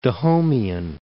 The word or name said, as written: Dahomean